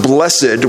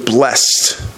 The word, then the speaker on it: blessed: Oftentimes it's pronounced blessed, which to me gives the idea that there's something within the person that is blessed or holy. I like the pronunciation blessed. That way you get the idea that the blessing is coming from the outside.